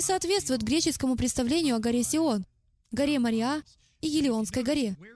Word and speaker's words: Сион: Гора Сион, которую некоторые называют гора Зион, но правильно говорить гора Сион, Гора Сион в еврейских писаниях и во всей Библии употребляется как синоним всего Израиля, всего Иерусалима.